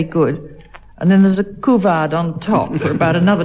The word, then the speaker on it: couvade: And now, Anne Scott James, your word is couvade, C-O-U-V-A-D-E, and in spite of my pronunciation, you'll perhaps recognize it's French in origin, couvade.